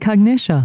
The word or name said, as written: cognitia